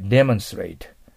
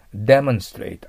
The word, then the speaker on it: demonstrate: でも英語の場合は、はい。 demonstrate。 demonstrate。アクセント前に持ってきて一緒に言わないとダメです。はい。